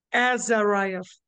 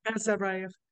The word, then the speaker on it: Azaraev: Am I saying that right? Azaraev. – Azaraev. – Azaraev. – Azaraev, yes.